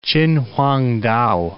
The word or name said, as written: Chinwangtao